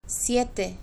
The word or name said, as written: siete